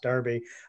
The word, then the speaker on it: Derby: I know that you guys spell it Derby, but it's pronounced Derby.